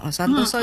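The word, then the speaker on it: Santosa: Santoso itu meskipun belakangnya hurufnya A, tapi bacanya Santoso ya.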